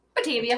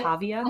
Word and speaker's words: Batavia: Did I say that right? – Batavia. – Batavia. Mm-hmm. Cool. – They go both ways.